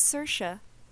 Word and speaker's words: Saoirse: And I was like, how the fuck do you pronounce Saoirse? And she was like, oh, it's Saoirse.